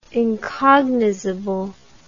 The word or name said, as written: incognizable